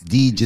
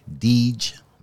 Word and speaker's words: Dij: Deej is spelled D-I-J, Deej, like for Khadijah.